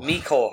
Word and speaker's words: Mecole: Is it Mecole Hardman? Is that right? – Mecole Hardman.